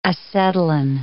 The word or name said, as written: acetylene